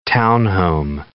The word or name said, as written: townhome